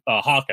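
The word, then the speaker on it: Haack: Matt Hack was Hawk, I should say. I always want to say Hack because the way it's spelled. Hawk was unbelievable.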